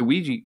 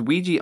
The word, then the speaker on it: Ouija: So, I've seen things, there's Ouija and Ouija. – Oh, okay. – And I'm sure there's going to be people like, it's pronounced Ouija. But I've looked it up, both are acceptable.